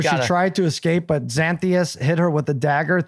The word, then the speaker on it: Xanthius: Number three, Exanthius, which is his own creation. – It's actually pronounced Xanthius.